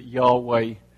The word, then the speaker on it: Yahweh: So that means that actually it's quite difficult for us to really know what the true pronunciation is, but most folks believe that Yahweh is probably about right.